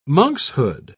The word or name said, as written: monkshood